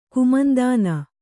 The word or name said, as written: ಕುಮಂದಾನ